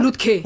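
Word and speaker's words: Lütke: Comme toujours, mais spécialement aujourd'hui, c'est pourquoi est-ce que les riches, alors c'est le PDG de Shopify qui s'appelle, comment tu le prononces d'ailleurs, Toby Lutke? Lutke, hein? – Lutke.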